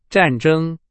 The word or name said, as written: 战争